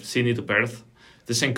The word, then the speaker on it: Perth: Perth? – Am I pronouncing it right? Like Perth.